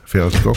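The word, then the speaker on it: Fältskog: Hier de zoon van Agneta Veldskuk, want zo schijn je dat te moeten uitspreken.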